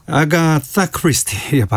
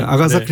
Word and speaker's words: 아가사 크리스티: 아가사 크리스티 발음을 좀 신경 써서 해봤습니다. 아가사 네.